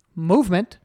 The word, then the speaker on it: MVMT: MVMT watches, pronounced movement. So movement watches.